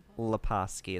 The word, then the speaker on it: Lepaski: Lepaski. It's like Batoski, but La.